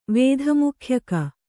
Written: ವೇಧಮುಖ್ಯಕ

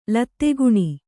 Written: ಲತ್ತೆಗುಣಿ

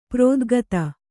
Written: ಪ್ರೋದ್ಗತ

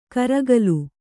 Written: ಗೋರಿಕಲ್

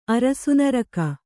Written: ಅರಸುನರಕ

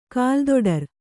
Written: ಕಾಲುತೊಡರು

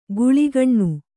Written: ಕೀಳ್ಗಣ್ಣು

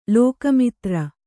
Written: ಲೋಕಮಿತ್ರ

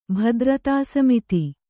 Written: ಭದ್ರತಾಸಮಿತಿ

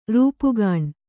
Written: ರೂಪುಗಾಣ್

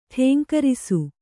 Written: ಠೇಂಕರಿಸು

